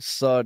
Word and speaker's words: Suh: Cause it's Sue, baby. That's how you say it.